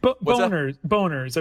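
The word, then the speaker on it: Bonerz: Boners, I believe the preferred pronunciation.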